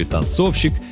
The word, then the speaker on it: танцовщик: Ударение в слове «танцовщик» точно так же падает на второй слог, как и в слове «танцовщица».